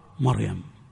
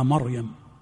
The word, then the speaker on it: مريم: أنا أقول للجميع هذا الكلام، كلمتان يكثر الخطأ فيهما، فرعون ومريم. يخطئ كثير من الناس فيفخم راء فرعون ويرقق راء مريم، يا مريم ها، ويرقق راء مريم فيقول يا مريم يا مريم اقنتي لربك. والصواب العكس فيهما، الصواب العكس فيهما.